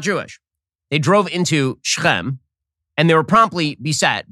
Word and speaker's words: Shechem: Hey, Shechem, or Shechem, as it's pronounced in English, is now an Arab city.